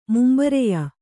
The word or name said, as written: ಮುಂಬರೆಯ